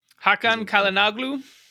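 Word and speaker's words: Hakan Çalhanoğlu: Hakan Kalinoglu.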